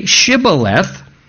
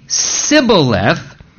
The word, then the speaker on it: Shibboleth: And they would say to them, well, then say Shibboleth. And he would say Sibboleth, for he could not pronounce it right.